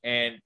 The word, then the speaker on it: A&N: This was a genius move that A&M Cold Cuts did. Did I say it right? – A-N-N. – A-N-N. I get it wrong every time.